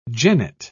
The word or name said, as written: genet